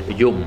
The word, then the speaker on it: Yom: Yom, ça se prononce Yom en khmer.